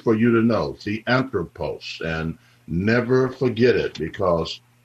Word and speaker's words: theanthropos: Theanthropos, theanthropos. Now, that word is spelled T-H-E-A-N-T-H-R-O-P-O-S, theanthropos. T-H-E-A-N-T-H-R-O-P-O-S, theanthropos.